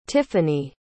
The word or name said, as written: tiffani